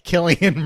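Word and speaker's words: Cillian: Wait, how do you say Sicilian Murphy? Killian Murphy.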